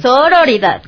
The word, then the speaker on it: sororidad: La sororidad. – Sonoridad. – Sororidad. – Sororidad. – A ver, pronuncia diferente. Es que sororidad.